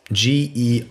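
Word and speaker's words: Gerês: It's the national park, Jerez. – Okay. – It's Gerez. – You guys have good Portuguese pronunciation, so props for that.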